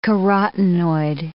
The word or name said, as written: carotenoid